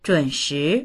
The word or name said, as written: zhun3shi2